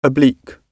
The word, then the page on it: oblique: British English